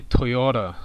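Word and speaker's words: Toyota: で、トヨタっていうところで、どこが強いのかなって言うと、真ん中になって、トヨタっていう風になります。まずアクセントですね。真ん中の YO の部分ですね。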